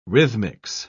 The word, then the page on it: rhythmics: British English